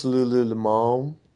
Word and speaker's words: Lululemon: It's pronounced Lululemon.